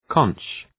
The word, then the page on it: conch: {kɒŋk}